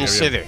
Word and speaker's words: Enschede: ¿En Schede puede ser? En Países Bajos, – Perfecto. ¿Lo he dicho bien? Sí, lo has dicho muy bien. Joder, en Schede.